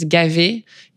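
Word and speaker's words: Gavet: And then in French, you usually don't pronounce the last letter. So my last name is Gavé. You don't pronounce the T.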